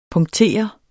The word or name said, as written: PUNKTERES